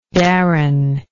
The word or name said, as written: Darren